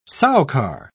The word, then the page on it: soucar: British English